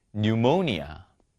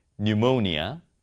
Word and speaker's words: Pneumonia: Psy, Psy, Pseudonym, Pseudonym, Pneumonia, Pneumonia, Psychology, Psychology.